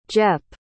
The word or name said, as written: jep